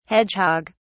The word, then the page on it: hedgehog(s): British English